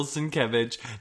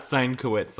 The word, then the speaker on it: Sienkiewicz: His name is pronounced Bill Sienkiewicz, not Sienkowitz, like I was trying to do earlier.